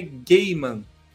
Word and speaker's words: Gaiman: E, e eu tava vendo é, uma maneira de pronunciar, que como se pronuncia o nome dele. Ele falou que é Gaiman.